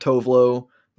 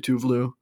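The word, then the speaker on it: Tove Lo: Kind of like we've mentioned, like Tovlo is the American pronunciation, even though it's actually Tuvlu.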